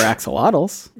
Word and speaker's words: axolotl: axolotl. – I love axolotls. Who doesn't? – Yeah. I mean, technically the pronunciation is axolot, but when it gets moved to English, we kind of just pronounce the L.